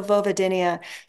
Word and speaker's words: vulvodynia: And then what is also, and this is another one, I don't know if I'm going to say it right, vulvodynia? – So vulvodynia.